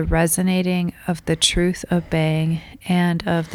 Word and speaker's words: Beyng: Reading through the text, I feel like I haven't reminded everybody yet that when we say bang, we are pronouncing B-E-Y-N-G, which is Heidegger's alternative spelling of B-E-I-N-G. And it's kind of a spatio-temporal event type of being, so it's different than B-E-I-N-G.